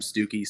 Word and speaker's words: Stooky: It's like such a common mispronunciation that people call it Stook, to where even people who know I'm Stooky still call me Stook.